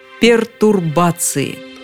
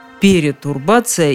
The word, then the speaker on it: пертурбация: Ведь в этом слове нет никаких пере, нет никаких труб. Иными словами, не стоит говорить перетурбация и перетрубация. В общем, раз уж мы так любим иностранные слова, давайте научимся их писать и произносить правильно. Пертурбация.